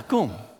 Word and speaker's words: cum: Well, how do you say that? Cum. Cum. Yeah. Yeah, cum. Yeah. Did I say that right? – Yeah, that's pretty simple.